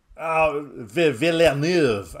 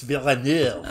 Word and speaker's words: Villeneuve: Villeneuve. Okay, Denis Villeneuve.